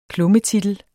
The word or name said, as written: klummetitel